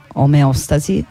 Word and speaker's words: omeostasi: Però, avendo fatto questi studi qui, mi ricordo che i miei professori e le mie professoresse pronunciavano quella parola omeostasi, e quindi io la dico così.